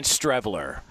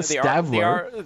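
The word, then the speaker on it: Streveler: I'm seeing Strevler. Chris. – Yeah, Strevler. Who wouldn't know that? Not Stevler. – The R is silent. – It's not Stevler.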